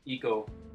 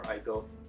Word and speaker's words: Ico: Yeah, it definitely borrows from Ico or Ico, depending on how you want to pronounce it.